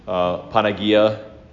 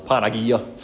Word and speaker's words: Panagia: Panagia. You want to do it with a southern drawl, Panagia.